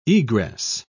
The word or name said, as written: egress